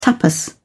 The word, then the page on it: tapas: British English